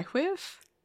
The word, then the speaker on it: WHWIV: It's W-H-W-I-V. Whiv? – Whiv. – Whiv? Why whiv? – W-H-W-Y. – Nope. No. W-H-W-I-V. – Whiv. – Yes, there it is.